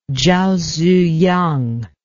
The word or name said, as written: zhao ziyang